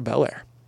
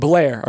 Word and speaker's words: Belair: It's Bianca Belair. It's, I mean, that's a different name. – That's tough, Alex. – That's a different name. I'm sorry. – Who's to say I just wasn't mispronouncing it like Illinois? – I'm sorry, but Illinois and Illinois are the same place, just with one letter accidentally said. Bel Air and Blair are two different names.